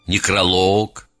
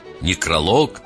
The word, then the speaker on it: некролог: Я об ударении. Договор, некролог, каталог. Я сознательно чуть-чуть утрированно сделал правильное ударение. Договор, некролог, каталог.